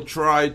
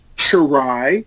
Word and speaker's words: try: Remember that all these words with T-R are spelled like with a C-H. Try.